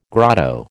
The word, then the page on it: grotto: American English